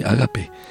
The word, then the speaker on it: ágape: El amor de Dios se define desde una palabra griega que se llama ágape, o que se pronuncia así, ágape, y significa el bienestar máximo de la otra persona.